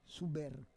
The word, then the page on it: suber: Roon